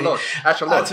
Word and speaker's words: axolotls: I'm imagining that also part of the reason why axolotls, pronounced correctly, axolotls with a little at the end, are popular is because they have become, in Japan, they have become super popular thanks to these anime versions of axolotls.